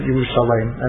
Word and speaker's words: Yerushalayim: Yeah, so right, so the truth is, that's really, if you look in the Tanakh and you didn't have the kudos, you would pronounce it Yerushalayim.